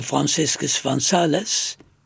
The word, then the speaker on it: Franciscus van Sales: Heel mooi is in de 17e eeuw aan het begin, heel mooi is dat de heilige Franciscus van Sales, laten we het zo maar uitspreken, want we zijn in Nederland,